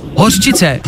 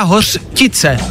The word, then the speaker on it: hořčice: Potom je tam slovo hořčice. Jak vyslovuješ hořčici? Hořčice, nemáš čo? No máš čo, jo? Spust... S čo? Nebo s čo? Čo? Čo? – č. – Spousta lidí říká hořtice, kamarádi.